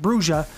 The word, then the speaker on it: Brujah: And some of you may pronounce it Brujah, but I'm going to pronounce it Brujah.